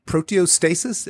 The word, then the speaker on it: proteostasis: So number three on these primary causes of aging is loss of proteostasis. Am I saying that right? – Yes, you are.